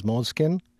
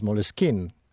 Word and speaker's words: Moleskine: Having said that, though, Moleskine as a word is originally an English word. So the English pronunciation is Moleskine.